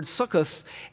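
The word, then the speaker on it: sukkoth (סֻכּוֹת): In the New King James, at least, it gives this word sukkoth, and it basically is just a transliteration. That's the Hebrew word. That's how you would pronounce it there, the sukkoth.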